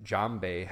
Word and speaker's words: djembe: I recently learned that djembe has a D in front of it.